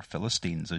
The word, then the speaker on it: Philistines: And indeed, it was named Palestine really to thumb the nose at the Jewish people, because it referenced back to the Philistines, or Philistines as you pronounce it over here.